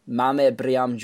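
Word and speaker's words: Mame Biram: I can't pronounce his first name, actually. That's one of the names. Mame Briam Diouf. There we go.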